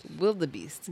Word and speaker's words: wildebeest: It's spelled WILDEBEEST. – Oh. – Wildebeest. – Wildebeest. – Oh, so that's why you weren't saying Wilder. – Because it's not it's not Wilder. Not because of my accent.